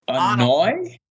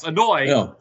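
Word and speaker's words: Anoy (Anoaʻi): And former Shield partner, Reigns, Leotide Joseph Anoa. How do you say the last name? – I think it's Anoy. – Yes, Anoy, 37.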